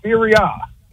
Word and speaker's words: Serie A: You said Series A. We pronounce it Serie A.